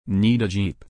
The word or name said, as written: need a jeep